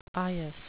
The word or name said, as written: eyas